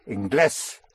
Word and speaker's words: Inglés: I N G L E S According to the nose rule, I would pronounce it English. – Then there's an accent. – Where? – Over the E. – Right. So I-N-G-L-E, accent over the E-S, English.